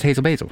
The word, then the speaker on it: Hazel Basil: Is it Hazel Basil? – No, it's Hazel Basil.